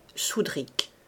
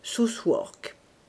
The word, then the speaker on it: Southwark: Southwark alors se prononçait Soudric. Aujourd'hui, on prononce Southwark, à peu près.